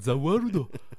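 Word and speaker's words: Za Warudo: Et donc c'est Zawaludo que t'as peut-être vu traîner sur internet Ou en gros euh, parce qu'il le prononce comme ça Zawaludo et ça arrête le temps.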